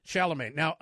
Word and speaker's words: Chalamet: Chalamet.